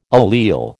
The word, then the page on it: allele: American English